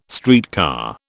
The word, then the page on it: streetcar: British English